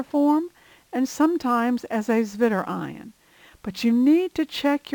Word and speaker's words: zwitterion: The W is pronounced as a V, a zwitterion.